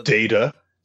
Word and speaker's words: Data: Data is how you say his name.